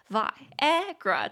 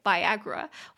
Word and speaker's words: Viagra: A study suggests that men who take drugs for erectile dysfunction, such as Viagra, may reduce their risk of Alzheimer's disease. 所以威尔刚的英文怎么说呢？就叫做 Viagra，Viagra，三个音节，Vi V I A A。 G R A Viagra，中音节在第二音节 Viagra。